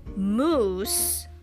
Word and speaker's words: mousse: You say mousse, not like the moose animal, but it's spelled M-O-U-S-S-E. Not like M-O-U-S-E, the mouse.